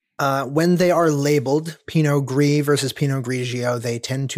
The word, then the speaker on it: Pinot Gris: But this is a Pinot Grige. – Pinot Gris. – Pinot Gris, G-R-I-S, I apologize. – G-R-I-S, the right. S is silent because it is en français.